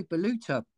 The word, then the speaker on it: Baluta: Yeah, Baluta, yeah.